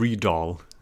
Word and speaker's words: Rydahl: utan han Gustav blev... Rydahl. Exakt så. – Perfekt uttal.